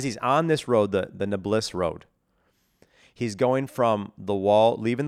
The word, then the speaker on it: Nablus: Nablus Road.